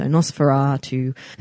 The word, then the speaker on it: NOS4A2: The book is spelled NOS4A2. So the A you pronounce R, so Nosferatu.